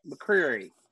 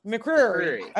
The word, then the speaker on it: McCreary: You got to say it like there's marbles in your mouth. Like it's like when I say Louisville. – It's when you say Louisville. So it's like McCreary.